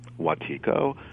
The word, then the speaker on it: Wetiko: Well, and just let me say, from my point of view, there's no one correct way of saying it, because I pronounce it Wetiko, but okay. It all depends how the winds pass through our channels as far as how we pronounce it, so it's really fine either way.